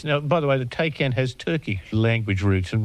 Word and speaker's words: Taycan: I might have said a Taycan, but it's not that, is it? – No, it's a Taycan. – T-A-Y-C-N.